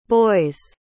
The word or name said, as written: boy's